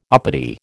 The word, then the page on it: uppity: American English